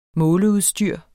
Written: måleudstyr

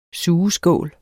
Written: sugeskål

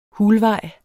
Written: hulvej

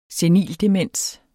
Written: senildemens

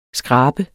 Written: skrab

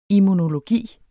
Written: immunologi

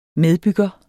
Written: medbygger

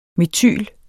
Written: metyl

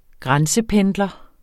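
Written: grænsependler

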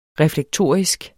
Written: reflektorisk

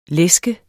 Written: læske

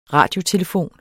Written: radiotelefon

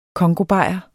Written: congobajer

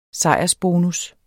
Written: sejrsbonus

